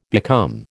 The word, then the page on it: become: American English